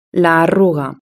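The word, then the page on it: la arruga: Spanish (Spain)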